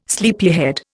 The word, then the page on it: sleepyhead: Indian